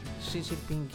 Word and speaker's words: Xi Jinping: Xi Jinping, eu falei certo.